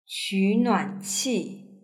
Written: qunuanqi

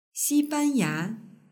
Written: xibanya

